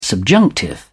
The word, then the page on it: subjunctive: British English